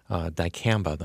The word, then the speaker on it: Dicamba: Dicamba, I'm pronouncing it correctly. Is that right? – Yep.